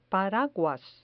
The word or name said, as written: paraguas